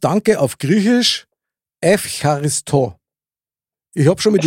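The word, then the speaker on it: Evcharisto (Ευχαριστώ): Evcharisto. – Ja. Evcharisto.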